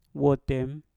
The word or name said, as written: wotem